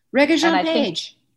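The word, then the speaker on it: Regé-Jean Page: Reggae Jean Page.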